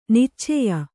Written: ನೈಚ್ಯ

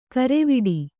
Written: ಗರ್ವಾಟ

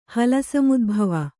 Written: ಹಲಸಮುದ್ಭವ

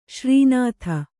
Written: ಶ್ರೀನಾಥ

